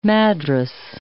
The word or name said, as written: madras